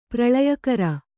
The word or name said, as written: ಪ್ರಳಯಕರ